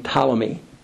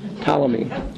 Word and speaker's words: Ptolemy: And the south, which is largely Egypt, the Sudan, all that area, is ruled by a general named Ptolemy. Once again, the P is silent like the P in swimming. Okay. Ptolemy.